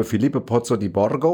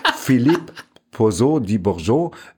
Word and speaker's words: Philippe Pozzo di Borgo: Das schreibt sich eigentlich wie ein italienischer Name, Filippo Pozzo di Borgo oder so, aber er spricht sich Philippe Pozzo di Borgo, weil das nämlich ein französischer Unternehmer war,